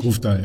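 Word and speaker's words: Grøvdal: Es wird auch ein sehr spannendes Debüt geben, nämlich mit von einer norwegischen Athletin, nämlich Caroline, ich glaube, Grofdal spricht man so aus, richtig? – Grofdal, ja.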